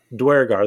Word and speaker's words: Duergar: I can never say the name right. – Duergar, there we go.